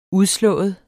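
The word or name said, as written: udslået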